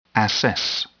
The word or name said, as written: assess